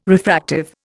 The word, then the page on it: refractive: Indian